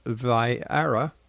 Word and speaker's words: Vieira: Exonation, Nation, my guest this hour is Jim Vieira. Did I say that right, Jim? – Uh, Vieira, like Meredith Vieira.